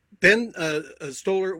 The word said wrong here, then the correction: Stiller: was was so nice. Did I get the name right? – Stiller, yeah. Ben Stiller, sure.